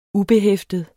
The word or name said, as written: UBEHEFTET